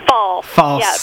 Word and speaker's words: Falce: And we're discussing Pine Mountain Settlement School with Megan Victoria Falce. Is that right? – It's false. – Sorry. – Like true or false.